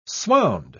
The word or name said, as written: swound